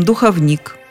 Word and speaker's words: духовник: А ударение всегда было именно таким – духовник. Оно не менялось веками. Духовник – духовника, духовники – духовников. В орфоэпическом словаре под редакцией Аванесова есть даже примечание «неправильно говорить «духовник». А словарь ударений Резниченко рекомендует правила для запоминания. Ориентируйтесь на слово «ученик». Ученик – духовник, ученика – духовника, ученики – духовники.